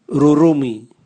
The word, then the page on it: rurumwi: Raga